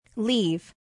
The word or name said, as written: leave